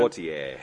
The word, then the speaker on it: Poitier: Poitier. – And our friend... – say it like he says it.